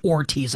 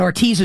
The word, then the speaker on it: Hortiz: There is an exchange after the season, I believe, at some point this summer maybe, where it's a Joe Ortiz, it's his name is spelled H-O-R-T-I-Z, and I believe it's pronounced Ortiz, like the H is silent, perhaps.